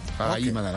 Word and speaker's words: Raharimanana: Raharimanana. Voilà. – Raharimanana. – Raharimanana. Toi, tu sais même pas dire ça. Raharimanana. – Raharimanana. – Très bien. Raharimanana.